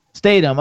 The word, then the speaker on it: Statham: Statham.